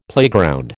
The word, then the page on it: playground: American English